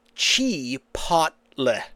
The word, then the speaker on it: Chipotle: So, the correct pronunciation that I'm reading on a few different posts here is Chipotle. Chipotle. – Ah, not Chipotle. Chipotle.